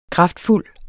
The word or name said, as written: kraftfuld